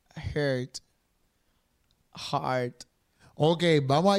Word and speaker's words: Heard: Y Amber Hard. Amber Hard. Hard. Hard. Heard. Heard. No sé cómo se dice la apellido. Es e H-E-A-R-D. Heard. Heard, Heard. Amber Heard.